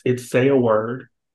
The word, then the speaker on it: SYA-WORD: So it's SYA-WORD, but it's SYA, because my first syllable, my name is SYA.